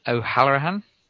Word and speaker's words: O'Halloran: Can you pronounce this guy's last name? – Brian O'Halloran.